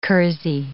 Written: kersey

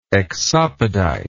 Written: exopoditic

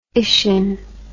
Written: ician

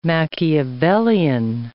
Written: Machiavellian

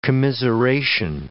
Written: commiseration

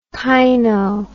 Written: caino

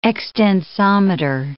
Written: extensometer

